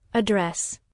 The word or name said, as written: address